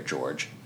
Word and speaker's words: Jorge: George? Well, that was Jorge. – Is it Jorge? – That's the way I saw it in the stuff that I was reading. – Okay. I didn't know how it's spelled J-O-R-G-E, which can be Jorge or George.